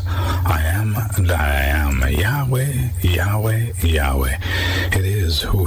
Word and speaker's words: Yahweh: This name in itself, God's name is almost always translated Lord, all caps, in the English Bible, but the Hebrew word be pronounced something like Yahweh, Y-A-H-W-E-H, and it's built on the word I am.